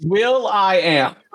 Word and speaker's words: will.i.am: It's Will.i.am.